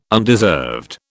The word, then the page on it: undeserved: British English